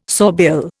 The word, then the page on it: sawbill: Indian